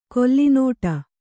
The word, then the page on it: ಕೊಲ್ಲಿನೋಟ: kolli nōṭa